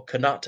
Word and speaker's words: Canute: to North America under King Canute, or Canute, as some people prefer to pronounce it, and they landed on the American continent, naming it Vinland, as in Vineland, what's now Newfoundland, Canada.